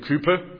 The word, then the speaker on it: Cowper: I'm going to pronounce him William Cowper. I know that for some pronunciation is Cowper, and you see a cow when you pronounce it as a cow,